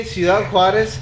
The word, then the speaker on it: Ciudad Juarez: I say Ciudad Juarez. That's the correct pronunciation.